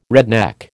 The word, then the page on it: redneck: American English